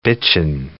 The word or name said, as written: bitchin'